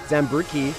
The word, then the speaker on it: Zambricki: Zambricki.